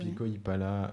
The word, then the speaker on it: Pico y Pala: Pico y Pala. – Donc P-I-C-O-Y-P-A-L-A, donc un peu comme ça se prononce. – Voilà, c'est l'avantage de l'espagnol.